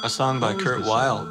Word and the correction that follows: Weill: It's pronounced Vile.